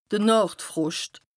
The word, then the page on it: de Nàchtfroscht: Bas-Rhin; Strasbourg